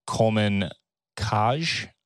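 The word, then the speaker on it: Coleman Kcaj: Coleman Kaj, I want to say is the pronunciation. – Yep.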